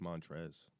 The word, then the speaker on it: Montrezl: You know you don't pronounce the L. It's just Montrez.